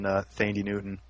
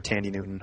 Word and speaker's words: Thandie Newton: Amanda Peet also starring, and then Thandie Newton, or Tandy Newton, however you want to pronounce that.